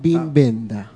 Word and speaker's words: Wim Wenders: Años pronunciándolo como Wim Wenders. – Mal, desde luego. Sí, sin ningún éxito. – Bueno. – Pero parece que la traducción está, es Bim Benda.